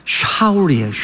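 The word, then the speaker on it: Saul: 근데 사울의 이름은 이게 히브리식으로 발음하면 샤울이에요.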